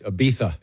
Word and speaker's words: Ibiza: She did it in Ibiza. – Oh, nice. – Huh. – It's Ibiza. – It's Ibiza, by the way. – Come on with that. – The Z is pronounced the TH.